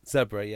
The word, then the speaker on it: Zebre: Then, Zebre, I think I'm saying that right. Mm-hmm. Host Connacht. – Hang on. Zebre, yes.